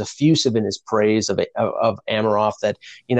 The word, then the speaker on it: Amirov: Well, I think Leaf fans who were critiquing the first-round pick of Rodion Amirov, or Amirov, I've been told that's the proper pronunciation,